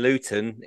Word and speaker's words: Luton: I also learned that the tea in Luton is silent. – It's more Lou, isn't it? – I think the Brighton one's an apostrophe.